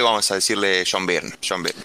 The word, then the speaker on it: Byrne: John Bern. no es tan difícil, loco. Bern.